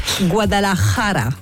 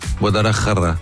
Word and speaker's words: Guadalajara: On dit Guadalajara. – Non. – Parce que la rota en espagnol se prononce R et le R R. Guadalajara. Vas-y. – Guadalajara.